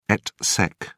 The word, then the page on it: et seq: British English